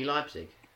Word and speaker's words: Leipzig: We've got how do you say that one? – RB Leipzig. – Leipzig. Yeah. Why did I want to say Leipzig? – Against against Monaco.